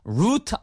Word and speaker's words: route: 네, 그래서 D-E-S-T-I-N-A-T-I-O-N 됐고요. 다섯 번째는 경치 좋은 도로 이렇게 쭉 운전해서 가고 싶은데 어떤 도로가 좋을까요? 그래서 R-O-U-T-E 발음은 두 가지입니다. Route 아니면 Route.